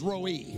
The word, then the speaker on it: Rohi: The Hebrew word there, for years I said it was Rohi. That's not the correct pronunciation. The correct pronunciation is Rohi. Yahweh is my Rohi, which means the Lord is my shepherd.